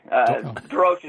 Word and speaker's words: Dorosh: Dorosh is usually how I say it. I think my dad said it a little differently and gives my mom heck for saying it the way she does.